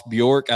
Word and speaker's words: Bjork: Now, do you believe the reports out there, Chris, that Ross Bjork, I think is how you pronounce the AD's last name, mm-hmm.